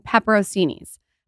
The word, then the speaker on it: pepperoncini: Then you take it out and then you cover the cheese and pepperoncinis. Pepperoncinis are the trick to a good sandwich. – Is that how you say it? – Pepper- no, probably not. – pepperoncini, but that's all right.